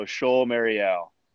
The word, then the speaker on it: Chol Marial: Is it Chol Marie? Am I saying it right? Or Marial? – So ch- Chol, like kind of like almost like sh. So Chol Marial.